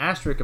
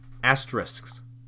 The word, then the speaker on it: asterisk: So it's spelled A-S-T-E-R-I-S-K. – So and a lot asterisk. – Asterisk. – Asterisk. – Asterisk. So the K is supposed to be the last part.